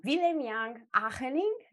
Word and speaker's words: Willem-Jan Ageling: Today, I have with me one of the most prolific writers of the community and also the founder of Serious Scrum, Willem-Jan Ageling.